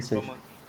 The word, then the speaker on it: Coman: Coman, não sei como é que fala. É o Coman, Coman.